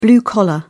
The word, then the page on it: blue collar: British English